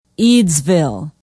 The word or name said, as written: eadsville